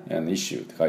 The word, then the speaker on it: issue: であれもね、イッシューって書いてありますよね。で、今日はそういうこの辺の発音をちょっとやっていこうかなと思います。では、じゃあこのイッシューなんですけども、まず、アクセントはこの最初スペルはですね ISSUE です。イッシューイッシューっていう感じなんですけども、最初の I にアクセントがありまして、